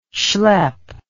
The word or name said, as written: shlep